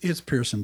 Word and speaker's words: Pearson: But here on Poor Decisions, we have like our cool announcer, Jack Spencer, said, did I say that right? Jack Spencer? – It's Pearson, but I've been called Spencer before.